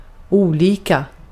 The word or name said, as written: olika